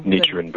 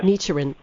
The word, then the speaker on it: Nichiren: You know, I think it's fascinating that you are a very long-time practicer of, it's Nichiren Buddhism. Did I say that right? – Right. Fine. Nichiren Buddhism, yes. – Nichiren. Thank you.